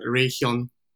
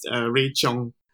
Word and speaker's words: Ray Chong: And my name was pronounced as a Korean name, Ray Hyun. So the right pronunciation, that sounds awkward, isn't it? Yeah. So the proper pronunciation is Ray Chong.